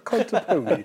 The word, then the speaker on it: Contepomi: So himself and Philip Contepomi were there, and they were just talking about Philip Contepomi. – Oh, you guys pronounced the E at the end of his.